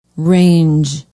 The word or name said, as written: range